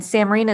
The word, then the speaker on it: Samreen: And Samreen is spelled S-A-M-R-E-E-N.